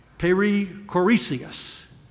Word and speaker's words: perichoresis: The theologians call it, and I always leave out one syllable. Sometimes Greek words are hard and little syllables throw in there, but if I can get it pronounced right, it's perichoresis.